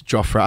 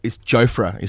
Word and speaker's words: Jofra: I think Jofra is the pronunciation that we're going with, actually. Jofra. I think it's hard to go away from Jofra when you've said it as often as we have, but Jofra, as a young lad, he was a leg-spinning wicketkeeper.